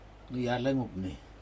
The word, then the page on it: niacag upni: Aneityum